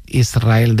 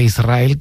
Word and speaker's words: Israel: Y de diminutivo, de chiquitito, Israel, que ella quería, porque Israel creo que le sonaba muy grande, ¿no? Israel, ¿no? – Y la S con la R es difícil de pronunciar.